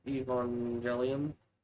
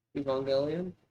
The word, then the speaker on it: Evangelion: Evangelion? Evangelion? Yeah, I think you said it right the first time, actually.